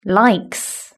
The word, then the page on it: likes: British English